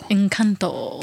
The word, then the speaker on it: Encanto: Say it with the accent. – Encanto. – Encanto. – That's how you say it, right? Yes, perfect.